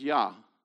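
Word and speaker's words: Yah (יה): So no question, the first syllable is Yah.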